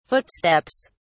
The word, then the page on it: footsteps: British English